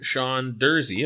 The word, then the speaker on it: Sean Durzi: Sean Durzi, am I pronouncing that correctly? – Yeah, spot on.